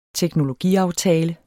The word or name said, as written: teknologiaftale